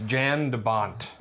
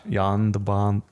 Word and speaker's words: Jan de Bont: You're saying his name wrong? It's Jan de Bont. – Jan de Bont.